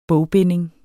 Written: bogbinding

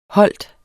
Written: holdt

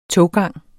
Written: toggang

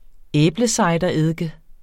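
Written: æblecidereddike

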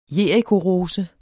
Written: JERIKOROSER

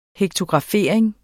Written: hektografering

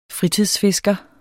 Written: fritidsfisker

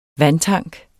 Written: vandtank

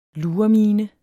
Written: luremine

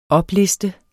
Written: opliste

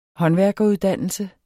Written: håndværkeruddannelse

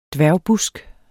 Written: dværgbusk

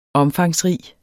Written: omfangsrig

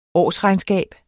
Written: årsregnskab

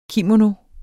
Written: kimono

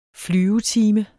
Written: flyvetime

